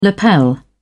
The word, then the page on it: lapel: British English